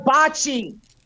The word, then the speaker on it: bocce: Bocce. Oh, am I saying that right? I'll let me consult our resident Italian. Is it bocce? – That's right, bocce.